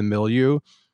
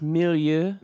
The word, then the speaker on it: milieu: milieu. And him just being there obviously kind of leads to all of these kind of, I believe it's pronounced milieu.